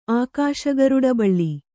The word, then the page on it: ಅಕ್ಷಗರುಡ ಬಳ್ಳಿ: akṣa garuḍabaḷḷi